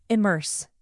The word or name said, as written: immerse